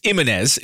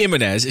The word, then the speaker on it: Ximénez: It's spelled X I M H-E-N-E-S. It's Jimenez. It's definitely Jimenez. It's just what the X does.